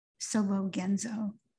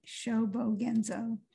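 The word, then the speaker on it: Shōbōgenzō: Here is the section of the essay on protecting and maintaining practice in Dogen Sobogenzo. I still said that wrong. Sobogenzo.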